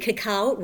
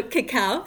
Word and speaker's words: cacao: And indeed, cacao rhymes with kapow. And I get that sort of visual image of the old cartoon as well. Bam, hoo, kapow. So cacao, C-A-C-A-O, rhymes with kapow.